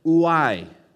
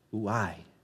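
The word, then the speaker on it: ouai (οὐαί): Now, the word woe, it's the Greek word, and I'm going to use this several times today, ouai, O-O-I, with an emphasis on the I, the accent, ouai.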